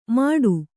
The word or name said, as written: ಮಾಡು